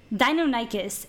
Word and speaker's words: Deinonychus: the Deinonychus? – Deinonychus. – It was the Deinonychus, which becomes the main villain of our movie. – The raptor. – He spoke several times about the Deinonychus.